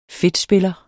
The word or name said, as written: fedtspiller